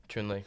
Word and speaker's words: Chunli: I think it's Chunil. I mean, it's spelled Chunil. – Interesting. – Zhao, 66. So he killed... Wait, let me just make sure I'm right. – It is Chunli. Chunli. – Chunli? – Yeah. – Spell it. C-H-U-N...